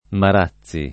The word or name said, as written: Marazzi